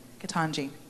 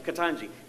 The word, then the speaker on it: Ketanji: Ketanji. – See, I was going to say Ketanji. But anyway, Ketanji. Yes, and so Ketanji, if you go, and I listened in on him.